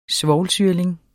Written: svovlsyrling

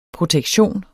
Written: protektion

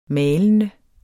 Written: malende

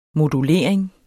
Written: modulering

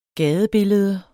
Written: gadebillede

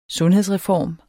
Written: sundhedsreform

